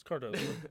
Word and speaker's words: Cardoso: Sorry, I'm sorry if I pronounced that wrong. – You did. – Okay, sweet. Did pronounce it wrong or right? – It's Cardoso.